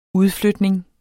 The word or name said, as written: udflytning